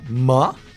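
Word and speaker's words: MØ: La artista se llama, se pronuncia Ma. Suena raro. porque el nombre se escribe M y una O con una diagonal en medio.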